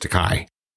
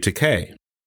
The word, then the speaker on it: Takei: I've been mispronouncing his name for my entire life. I've always said George Takei. And it turns out, and I found this out before the interview, thankfully, it's actually Takei.